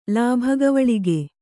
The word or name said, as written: ಲಾಭಗವಳಿಗೆ